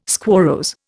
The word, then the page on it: squarrose: Indian